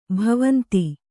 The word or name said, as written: ಭವಂತಿ